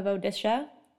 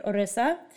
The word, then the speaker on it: Odisha: It's a cultural heritage village in the state of Odisha. I also said that you can pronounce it Orissa.